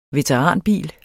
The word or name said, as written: veteranbil